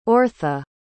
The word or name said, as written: orrtha